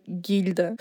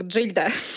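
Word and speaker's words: Gilda: Были другие правила чтения, или я не знаю что, но девушку из фильма, правда, называли Гильда. Хотя по всем правилам чтения это читается как Джильда.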